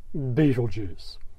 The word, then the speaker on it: Betelgeuse: Some people pronounce it as Betelgeuse or Betelgeuse, but they're all mispronunciations of the old Arabic name Ibt al-Yaza, the hand of the big man, or, as we call it now, Orion's second brightest star, Betelgeuse.